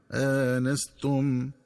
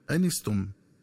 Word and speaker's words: آنستم: ينبغي التنبه للنطق الصحيح للفظ آنستم، فيقرأ بهمزة مفتوحة بعدها ألف مع فتح النون هكذا آنستم، ومن الخطأ قراءته بحذف الألف وكسر النون هكذا أنستم.